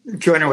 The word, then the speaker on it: Joiner: Is that Joanier? How do you pronounce that? How do you Joiner with an I, yeah.